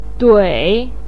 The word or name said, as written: dui3